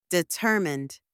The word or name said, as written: determied